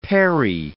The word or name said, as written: perry